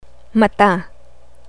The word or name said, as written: mata